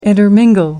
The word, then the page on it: intermingle: American English